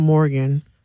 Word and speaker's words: Morgan: My Facebook is Jatan Morgan. – Spell that. – J-A-T-A-U-N, last name Morgan, M-O-R-G-A-N.